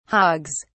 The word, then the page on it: hogs: British English